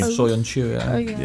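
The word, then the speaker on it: Söyüncü: Soyuncu, yeah.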